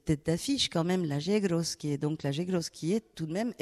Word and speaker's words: Yegros: Alors toi, tu prononces bien, parce que nous, on dit, nous, justement, nous, pour les non-hispanophones d'Argentine, on dit la Yegros pour nous.